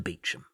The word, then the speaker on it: Beauchamp: that it's not said how it's written. It's spelled Beauchamp.